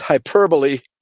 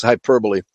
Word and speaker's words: hyperbole: Oh, by the way, it's not intuitive, but the pronunciation is hyperbole. It's very rare in English that the letter E is pronounced after an O-L-E, but in this case it is. It's hyperbole, exaggeration, okay?